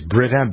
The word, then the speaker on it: Brita: That's how you pronounce the filter company, right? It's Brita, B-R-I-T-A.